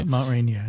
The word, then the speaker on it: Rainier: Yeah, Mount Rainier.